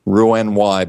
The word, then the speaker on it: Roux-en-Y: And this is spelled R-O-U-X hyphen E-N hyphen and then the letter Y, Roux-en-Y bypass.